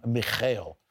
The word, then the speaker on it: Mikhail: That's Michael Fedorov. Actually, he pronounces his name Mikhail, as most people do in Ukraine or in Russia.